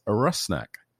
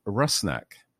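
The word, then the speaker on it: Rusnák: So that you can all be better prepared for my goal-scoring announcements this season, the pronunciation of our new designated player's name is Rusnak. Albert Rusnak.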